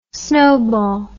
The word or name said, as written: snobol